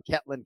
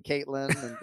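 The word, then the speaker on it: Ketlen: He's calling Ketlin, Caitlin.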